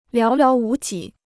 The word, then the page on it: 寥寥无几: liáo liáo wú jǐ